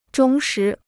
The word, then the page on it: 忠实: zhōng shí